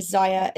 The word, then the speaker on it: Zyia: And again, Zaya is Z-Y-I-A, rhymes with papaya.